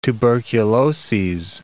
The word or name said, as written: tuberculoses